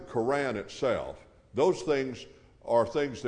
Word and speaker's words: Quran: The Quran from Allah, and that's the way you pronounce it.